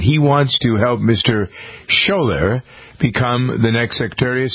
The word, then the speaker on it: Schoeller: The story from Missouri about a young fellow named Shane Shaler, I believe is how you pronounce his name, S-C-H-O-E-L-L-E-R.